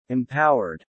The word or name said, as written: empowered